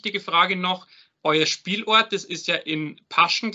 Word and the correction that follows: Pasching: glaube ich, heißt oder Pasching. Pasching, genau. Falsch aussprechen, äh, ist ja quasi jetzt eure äh, Neuheimat, also oder seit ein paar Jahren eure Heimat.